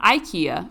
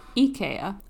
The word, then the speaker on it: IKEA: It's not IKEA, it's IKEA. And I learned that from an IKEA store employee.